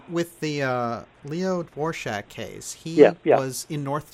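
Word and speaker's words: Dvořák: I don't know how the Americans would pronounce that. We say Dvorak in Czechoslovakian.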